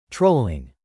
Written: trolling

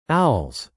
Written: owls

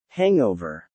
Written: hangover

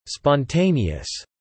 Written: spontaneous

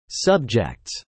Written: subjects

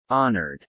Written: honored

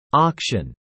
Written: auction

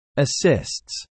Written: assists

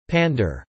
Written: pander

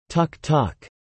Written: tuk-tuk

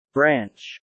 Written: branch

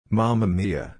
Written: mama mia

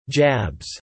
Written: jabs

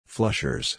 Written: flushers

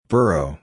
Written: burrow

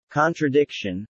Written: contradiction

